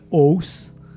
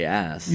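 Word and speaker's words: Aass: There's one that's spelt ass, but you pronounce it os. – No, I just say ass.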